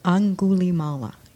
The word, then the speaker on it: Angulimala: Because I think, I can't pronounce his name well, Alugobi? – Angulimala? – Angulimala. Yes, Angulimala.